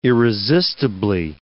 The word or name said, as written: irresistibly